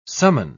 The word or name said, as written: summon